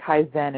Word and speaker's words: Kaizen: now tell me, what does Kaizen. Am I saying that right? Kaizen? – Have to no. – Is it Kaizen? – Kaizen. Well, it is. Sheila, that is a Japanese word. It's Kaizen. And I make a big point of referencing Kaizen in my book. And since it is derived from the Japanese, there are alternate pronunciations for that. I've heard a number of things, so don't feel bad. I pronounce it Kaizen.